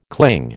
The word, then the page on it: cling: American English